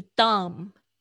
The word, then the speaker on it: dumb: 是 dumb，它也不叫。他也不说 dumb，就是很笨、很蠢的这个字是 d u m b，这个 b 就是这个不发音。